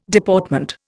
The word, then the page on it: Deportment: Indian